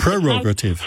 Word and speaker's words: prerogative: The word prerogative, I know you don't spell it prerogative, it's prerogative. – Prerogative, how do you pronounce it? – Pronounce it prerogative. – Oh, with the R in, yeah.